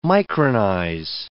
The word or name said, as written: micronize